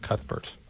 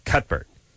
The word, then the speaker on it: Cuthbert: I don't think you do the th. I think it's the cut. You do it like that, Cuthbert.